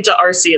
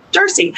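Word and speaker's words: D'Arcy: It's silent, by the way. Don't say Darcy. That's not how you say it. You say Darcy.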